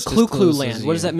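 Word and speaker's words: Clu Clu Land: And that for those of you that are not familiar, it's spelled C-L-U space C-L-U space land. Clue Clue Land.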